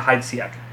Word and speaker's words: Heidsieck: Bernard Heidsieck. – Okay. – Heidsieck. We'll say Bernard Heidsieck.